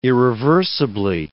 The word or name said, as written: irreversibly